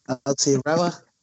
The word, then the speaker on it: Aotearoa: Proper pronunciation there for the listeners there, mate. Aotearoa.